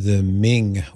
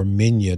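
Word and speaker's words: Migne: would be the Ming or Minya, depending on how you choose to pronounce it, collection, both of the Latin church fathers, in other words, that they wrote in Latin, or the Greek church fathers.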